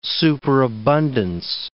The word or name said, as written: superabundance